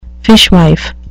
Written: fishwife

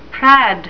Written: prad

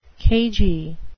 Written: cagey

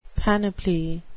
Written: panoply

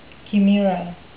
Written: chimera

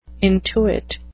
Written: intuit

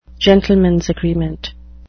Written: gentlemen's agreement

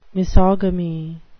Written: misogamy

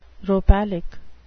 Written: rhopalic

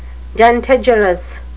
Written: dentigerous

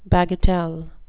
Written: bagatelle

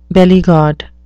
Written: bellygod

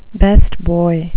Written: best boy

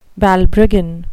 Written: balbriggan

